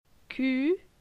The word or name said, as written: Cu